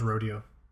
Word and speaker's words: Rodeo: It's pronounced Rodeo.